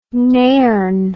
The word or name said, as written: nairne